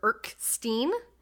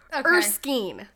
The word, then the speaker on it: Erskine: I called him Dr. Erkstein, but it's Erskine.